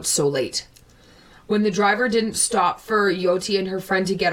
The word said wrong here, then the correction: Jyoti: So Diana just brought it to my attention that I was saying it wrong. It's Jyoti, so I'm going to start saying it like that now.